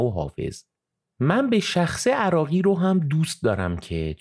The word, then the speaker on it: عراقی: عراقی که تلفظ درستش عراقی هست